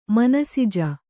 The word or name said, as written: ಮನಸಿಜ